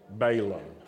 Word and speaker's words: Balaam: And in this week's readings, we learn that Balaam, in the south, how do you say Balaam? Balaam. Yeah, not Balaam. Balaam. That's right. Okay, we're going to learn good southern pronunciation.